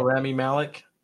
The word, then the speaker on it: Rami Malek: How do you say his name? That was got to play Freddie Mercury, right? – Oh, Rami Malek.